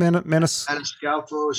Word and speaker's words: Maniscalco: Maniscalco.